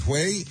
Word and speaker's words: Hue: And how do you pronounce it? Hue, 1968, A Turning Point of the American War in Vietnam. Did I get that right? – Yes, you did.